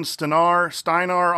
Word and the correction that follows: Steiner: on Twitter. – Steiner. – Steiner, thank you.